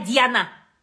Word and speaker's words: Диана: Диана дейді ғой. Диана? Ана, ударение арқа қарай кетіп қалды. Ай, Диана.